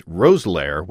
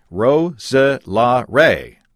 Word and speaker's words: Roeselare: Your guest pronounced Roselaire, R-O-E-S-E-L-A-R-E as Roselaire, which is wrong. This word has got four syllables, Ro-se-la-re.